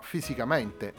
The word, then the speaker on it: Fisicamente: Il secondo brano che andiamo ad ascoltare si intitola Fisicamente, se lo leggiamo all'italiana probabilmente nella mente di Mario Laghigna che è compositore di questo brano si leggerà Fisicamenci.